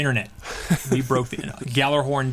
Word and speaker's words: Gjallarhorn: They had somebody who speaks native Icelandic, and they talked about the pronunciation, and for them in Icelandic, and it is Gjallarhorn.